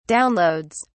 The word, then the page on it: downloads: British English